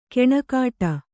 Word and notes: ಕೆಣಕಾಟ: keṇakāṭa

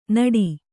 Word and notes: ನಡಿ: naḍi

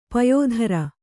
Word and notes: ಪಯಿತ್ರ: payitra

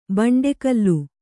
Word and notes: ಬಂಡೆಕಲ್ಲು: kannada

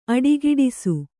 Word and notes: ಅಡಗಟ್ಟಿಸು: Kannada

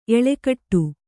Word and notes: ಎಲ್ಲೆಕಟ್ಟು: ellekaṭṭu

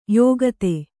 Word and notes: ಯುಕುತಿ: yukuti

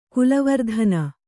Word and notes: ಕುಲವರ್ಧನ: kulavardhana

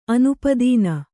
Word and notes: ಅನುಪಾದಾನ: anupādāna

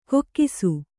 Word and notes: ಕೊಕ್ಕಿಸು: kokkisu

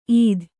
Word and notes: ಈದ್: īd

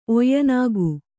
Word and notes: ಒಯ್ಯನಾಗು: Kannada

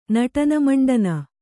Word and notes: ನಟನಮಂಡನ: naṭana maṇḍana

